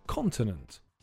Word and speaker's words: continent: The answer is continent. The stress is on the first syllable, so it's continent.